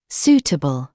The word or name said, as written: suitable